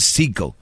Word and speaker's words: Sicko: Vean un, documental de Michael Moore que se llama ¿Es Psico o Psycho? creo que es Psycho. Eh, se escribe S-I-C-K-O.